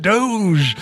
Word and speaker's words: Doge: Now I know it's pronounced Doge. – I have to say, I'm sorry. I'm sorry because I wondered the same thing, and I didn't want to admit it, but I wondered the same thing for a while. I mean, how do you pronounce this word? – I, I think everyone who says Doge is just saying it wrong, and they heard somebody say but the people who created Dogecoin are that's what they call it.